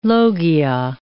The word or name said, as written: logia